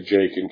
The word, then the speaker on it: Jakin: Their names were, it's Yakin, I think is how it's pronounced, Yakin, but we just say Jakin because that's what it looks like.